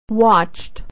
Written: watched

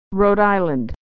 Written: Rhode Island

